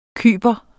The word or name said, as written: kyper